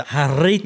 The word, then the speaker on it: Jarritos: You gotta roll your R's up. – The Jarritos.